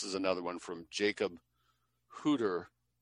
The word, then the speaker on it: Hutter: The founder of the Hutterites, Jacob Hutter, is how it'd be pronounced in German, established the Hutterite colonies on the basis of the Schlechtheim.